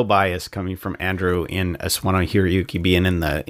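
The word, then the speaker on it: Sawano Hiroyuki: I would totally probably said Hiro Yuki a long time ago, back when I used to not pronounce things well. No, Suwana Hiroyuki.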